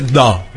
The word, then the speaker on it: DAW: Se pronuncia DO, pero se escribe DAW.